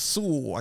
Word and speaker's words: Sewell: This is by Anne Sewell. I think that's how you pronounce her last name. And I'm saying Sewell because, you know, the Cadillac. – Yeah. It's Sewell. I got a Sewell.